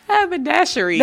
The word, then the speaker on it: haberdashery: Haberdashery. – But she still can't say it right. – Haberdasher. – Hey, look, you already have it. Haberdashery. – Haberdashery. – That's it.